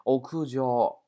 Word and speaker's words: 屋上: 屋上这两个单词的话，我们从来没学过上读成交，好像对吧？哎，屋的话也从来没学过，屋我们以前学的叫叫黑呀，不屋对吧？而这里边读成屋。啊，这个音要注意了。 o k u j o 啊，我感觉同学们呢，你们现在把单词啊背到现在的话，其实应该很简单了啊，我感觉，嗯 o k u j o 啊，屋顶的意思。